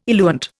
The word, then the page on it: eluant: Indian